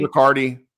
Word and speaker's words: Riccardi: Like, as much as I like Matt, how do you say his last name? Riccardi? – Riccardi.